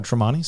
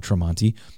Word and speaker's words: Tramonte: If you want to scout us out and just see if we're full of bull, Google my name, Todd Tremonti. Spell it as best you can. It is, in fact, T-R-A-M-O-N-T-E, Todd Tremonti.